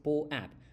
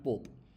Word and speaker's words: POAP: So the reason why it is PoApp, like this is how you have to say it, right? It's pronounced as PoApp, not Pope. So P-O-A-P, which stands for Proof of Attendance Protocol.